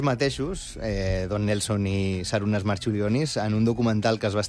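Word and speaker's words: Marčiulionis: Jo crec que li diuen Marciulionis, però... Marciulionis. Perquè que aquesta C es pronuncia com una TX.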